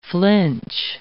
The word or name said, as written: flinch